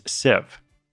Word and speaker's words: sieve: The correct pronunciation of that word is sieve. It's spelled S-I-E-V-E.